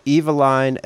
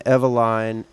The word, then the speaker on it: Eveline: He said that it was called Eveline, Eveline, Evelyn. Different people have pronounced it differently, and there's no one authoritative, source of how to do it.